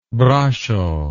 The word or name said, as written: brasso